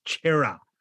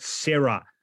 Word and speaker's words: Chera: We've just had a message, by the way, from the entire Italian community of Melbourne who want you to know that the pronunciation is Chera, not Sarah.